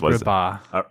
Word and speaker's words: Agrabah: sitting in a dark theater, partaking of the wonders of Aragbia. How is it pronounced? – Agrabah. – Aragbah. What is Agrabah. – It? – Agrabah. – Agrabah.